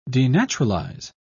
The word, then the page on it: denaturalize: British English